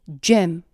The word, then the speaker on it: gem: 그리고 발음은 gem, G-E-M 에서처럼 soft G, game, G-A-M-E 에서처럼 hard G 두 가지 방법이 있으며 soft G 사운드는 사실상 J 사운드와 같은 발음이기도 합니다.